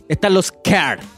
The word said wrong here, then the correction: queer: ¿Care se escribe? – No, care. – Queer. – ¿Queer? Ya, ahora cuéntame a mí, concha tu madre, con la pronunciación. – Deletréalo. – Q-U-E-E-R. – Queer, po, queer. – Queer. Ya, queer.